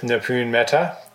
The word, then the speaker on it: Nipun Mehta: So here we are, Charles Eisenstein speaking with Nipun Mehta.